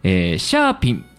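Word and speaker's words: 餡餅: それは買わなかったのですが、チーズスティックと、シャーピンというものを初めて食べました。あんもちって書いてね、シャーピンって読みますよね。チーズスティックは危険な美味しさでいくらでも食べられそうでしたが、私も食べましたよ。美味しかったですね。熱々でしたけどね、油で揚げて。シャーピンは思いのほか量が多すぎて食べきれず、今自宅冷蔵庫内で冬眠中です。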